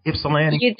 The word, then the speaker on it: Ypsilanti: Did I say it right, Ypsilanti? – You did very well.